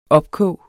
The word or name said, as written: opkog